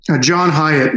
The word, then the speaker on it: John Hyatt: John Hyatt, yes.